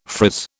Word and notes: frizz: British English

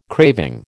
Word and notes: craving: American English

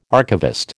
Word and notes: archivist: American English

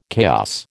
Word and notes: chaos: American English

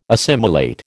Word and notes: assimilate: American English

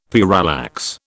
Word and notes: parallax: British English

